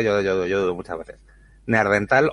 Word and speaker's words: Neandertal: ¿Neandertal o neandertal? ¿Cómo se dice? – Neandertal. – Vale.